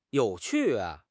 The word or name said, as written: you3 qu4